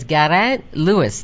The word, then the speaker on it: Garrett Lewis: And Gareth Perry here at IHI said I should refer to you as Garrett Lewis. Do I have that sort of right? – I'm very impressed, yes. – Okay. – That's right, that's the Welsh pronunciation.